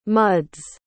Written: muds

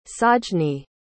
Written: sajni

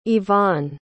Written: ivaan

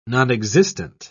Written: nonexistent

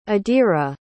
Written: adira

